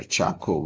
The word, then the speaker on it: Chacko: And my name is Reverend Dr. Chaco Varghese.